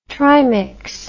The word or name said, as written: trimix